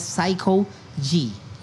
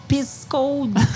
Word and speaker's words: Saykoji: they should say Psycho G, but the Indonesian time they call Pisco G, Pisco G.